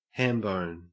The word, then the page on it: hambone: Australian English